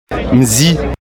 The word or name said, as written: mzi